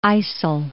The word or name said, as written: IJssel